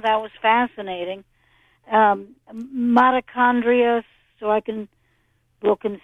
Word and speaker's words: mitochondria: So mitochondria, if would be uh, how you would pronounce it, but the H, oh. like it often is, is silent.